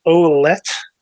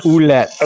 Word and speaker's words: Ouellette: Mike Olette I think, is this the way you pronounce his name? – He Ouellette. Posted in the Ouellette. Okay, Ouellette. I need some Canadian help there.